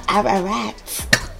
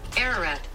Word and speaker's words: Ararat: Ararat.